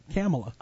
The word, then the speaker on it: Kamala: Well, I called her that, too, until I heard her say her name. Kamala.